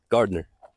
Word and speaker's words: Gardner: G-A-R-D-E-N-E-R. – Yep. Nope. People spell it like that all the time. – How do you spell it? – G-A-R-D-N-E-R. – Ooh, I like that. – Yeah. – It's like kind of like, you know, it's like got a little, it's like the N silent.